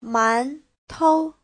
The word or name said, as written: man2tou5